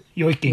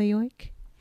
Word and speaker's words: yoik: She ends up singing, how do you say it? Is it a yoika? – Y- yoik, yeah, yoiking.